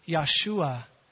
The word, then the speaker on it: Yeshua: Well, the actual word pronounced in the Old Testament for salvation is Yeshua. Everybody say Yeshua. The Hebrew people didn't have a sound for J. Instead, it was more like a Y sound.